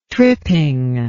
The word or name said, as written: tripping